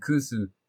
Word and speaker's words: kusu: Obviously, kusu, as you mentioned, kusu, for the uninitiated, is the Okinawan pronunciation of koshu, which most sake fans will understand is aged or matured.